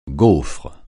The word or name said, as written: gaufre